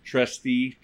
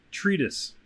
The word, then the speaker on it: treatise: How do you say that? Trustee? Treest? – Treatise. – Treatise? – I would assume. – I don't know how to fucking pronounce it. Oh, there we go. Look at the pronunciation part. A treatise.